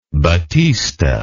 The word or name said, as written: batista